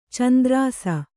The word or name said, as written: ಚಂದ್ರಾಸ